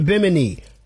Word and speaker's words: Bimini: Don't miss nonstop entertainment, Nassau Festival performances, and the Beach Club Party in Bimini. Did I say that right? – Yes, you did.